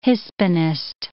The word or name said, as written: Hispanist